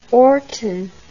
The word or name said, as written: orton